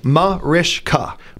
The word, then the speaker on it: Mariska: But the correct pronunciation is Mariska. – Mariska. – Mariska. – So there's a sh in there. Mariska. Sh. Okay. – It's got a little almost Yiddish, it sounds.